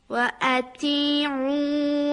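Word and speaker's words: أطيعوا: خب این دفعه میدونید شما یک اشتباه کوچکم داشتید و اون همزه ایست که در اول اطیعوا هست، اون رو مثل عین تلفظ کردید. یک بار دیگه بخونید تا تصحیح بشه. – وَأَتِيعُوا وَأَنفِقُوا خَيْرًا لِأَنفُسِكُمْ.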